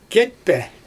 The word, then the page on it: ketpE: Xyzyl